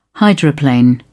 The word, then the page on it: hydroplane: British English